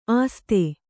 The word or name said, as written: ಆಸ್ಥೆ